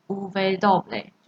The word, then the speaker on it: uve doble: 接下来是 w，w 的话，它在西文就是两个 v 组成而成，所以它会念成 uve doble，就是 u double uve doble，或是有人会念 doble uve，doble uve。